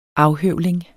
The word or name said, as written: afhøvling